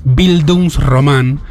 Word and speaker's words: Bildungsroman: expertos en literatura eso se pronuncia en alemán, se dice Bildungsroman, por ejemplo el de Mian de Hermann Hesse, claro.